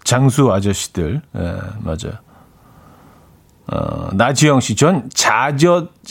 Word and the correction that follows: 잣엿: 자젓, 저 발음이 잣엿이 맛있던데. 어, 이렇게 똑바로 읽어야지.